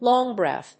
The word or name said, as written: long breath